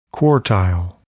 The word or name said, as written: quartile